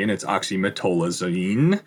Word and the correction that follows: oxymetazoline: which is, I didn't say it right. Say it right, Greg. – Oxymetazoline is how I think it's pronounced.